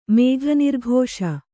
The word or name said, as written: ಮೇಘನಿರ್ಘೋಷ